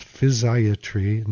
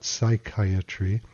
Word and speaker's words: physiatry: It's pronounced physiatry, not psychiatry.